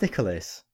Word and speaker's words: Nicalis: how is it pronounced, Nicholas or Nicalis or I think it's Nicholas. I think it's Nicholas. – Just like the men's name Nicholas.